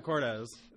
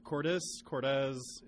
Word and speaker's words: Cordes: I'm still not 100% sure on how to pronounce my own last name. I'm a pretty mumbly person, so it's just like, Cordes. And yeah. Then I just let it go. But it has, you know, Cordes, it's two syllables.